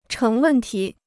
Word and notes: 成问题: chéng wèn tí

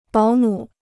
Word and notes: 保姆: bǎo mǔ

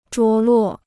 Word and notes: 着落: zhuó luò

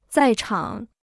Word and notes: 在场: zài chǎng

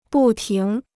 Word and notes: 不停: bù tíng

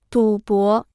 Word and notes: 赌博: dǔ bó

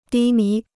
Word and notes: 低迷: dī mí

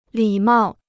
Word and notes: 礼貌: lǐ mào

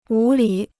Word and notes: 无理: wú lǐ

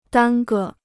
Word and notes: 耽搁: dān ge

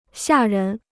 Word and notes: 吓人: xià rén

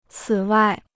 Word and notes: 此外: cǐ wài

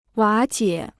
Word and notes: 瓦解: wǎ jiě

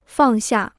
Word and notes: 放下: fàng xià

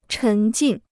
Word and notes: 沉浸: chén jìn

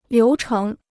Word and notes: 流程: liú chéng

